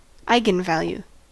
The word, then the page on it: eigenvalue: American English